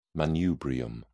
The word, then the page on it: manubrium: British English